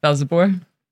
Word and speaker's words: Velzeboer: How do you say your surname? – Velzeboer. – Velzeboer. Portuguese or something? – Dutch. – Oh, that's Dutch. Okay. – Yeah, Velzeboer. – Oh, B-O-E-R.